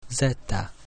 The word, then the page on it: Z: Latin